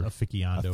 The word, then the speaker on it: aficionado: It's pronounced aficionado. – Aficionado, as I hear.